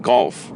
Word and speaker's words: golf: Having played golf, the wolf in wool rested on the toothstool in the zoo. 首先我们来看第三个单词 golf. Golf, 注意 G-O-L-F, 这个 O 的发音呢不是 O 啊，不要念成 golf, 高尔夫球，要念成 golf. Golf, O, 短音啊。